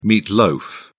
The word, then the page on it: meat loaf: British English